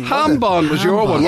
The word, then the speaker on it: jambon: I think ham we looked it up at the time and it is hambon. – There you go. – Yeah, beg your pardon. – Is it still spelled with the J? – Yeah, but you don't pronounce it like, you know, it's like you have to go, huh, it's a hambon. That's all it is.